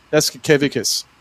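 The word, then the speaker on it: Jasikevičius: Serranus Yakisevicius. That's how you say it. – Yaskevicius, yeah. – Yeah, right. Yeah. Serranus Yaskevicius, yeah.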